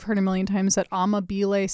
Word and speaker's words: Amabile: Amabile. Supposedly that's how you're supposed to pronounce Joe's last name according to a true Italian.